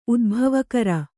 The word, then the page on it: ಉದ್ಭವಕರ: udbhavakara